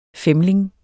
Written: femling

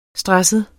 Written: stresset

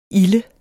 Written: ilde

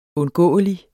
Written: undgåelig

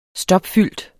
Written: stopfyldt

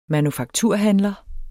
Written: manufakturhandler

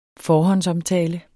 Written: forhåndsomtale

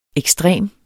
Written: ekstrem

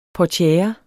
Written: portiere

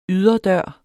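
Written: YDERDØR

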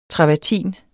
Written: travertin

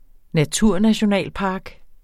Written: naturnationalpark

